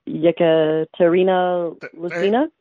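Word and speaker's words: Yekaterina Lisina: I'm not sure how to pronounce her name, but I do see. Yekaterina Lucina? – Thank you. There you go.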